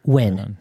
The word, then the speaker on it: Nguyen: Nguyen. That's how it's pronounced. Nguyen.